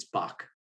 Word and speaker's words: Backe: Don't ask me why, but they did that to a lot of immigrants, and so uh, it's Bach. It's pronounced like the composer, but it's uh, spelled B A C K E.